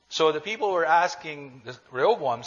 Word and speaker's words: Rehoboam: After Solomon's death, his son, Rehoboam, am I pronouncing that well? Okay.